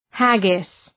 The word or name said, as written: haggis